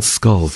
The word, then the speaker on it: Schull: It's pronounced... spelled S-C-H-U-L-L, but still called Skull for some reason.